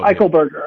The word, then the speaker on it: Eichelberger: Did I say that right? Eichelberger. Tell us about Eichelberger. – Him. Robert Eichelberger. Go ahead. – You got it.